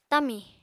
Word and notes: tamì: apatani

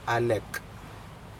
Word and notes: alec: Aneityum

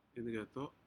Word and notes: incedo: Aneityum

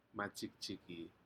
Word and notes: majikjiki: Aneityum

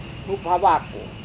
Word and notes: hup-havako: Serewen

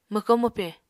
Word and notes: mwko-mwpe: Apatani

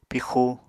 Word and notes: pixu: Apatani

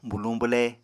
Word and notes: buluñ-bwle: Apatani

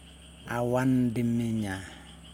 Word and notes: awan diminja: Yokoim

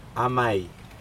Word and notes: amai: Aneityum

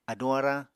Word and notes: aduñ-arañ: Apatani

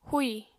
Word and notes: húì: apatani

